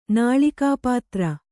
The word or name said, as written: ನಾಳಿಕಾಪಾತ್ರ